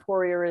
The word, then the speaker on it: Poirier: Any way you want. Poirier is kind of the way I grew up.